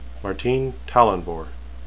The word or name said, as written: tallanvor